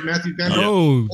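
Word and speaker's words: Mathieu van der Poel: Matthew Vanderpool.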